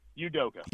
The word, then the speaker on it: Udoka: Udoka.